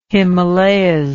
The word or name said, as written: himalayas